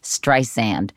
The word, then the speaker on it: Streisand: I have to say her name correctly, Streisand. Is that happening? – I simply don't know, because I'm now off that. I wrote a version of the script, and I worked with Miss Streisand for a while, which I enjoyed very, very much, and I liked her a lot.